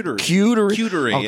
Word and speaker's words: charcuterie: What is it? – Charcuterie. – Charcuterie. Okay, yeah. – See, because I was saying charcuterie.